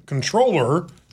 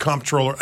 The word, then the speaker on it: comptroller: I'm going to stick with comptroller. I don't care if it's correct or not. – Why make it an M and a P if it's just going to be pronounced controller? – I agree.